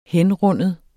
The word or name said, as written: henrinde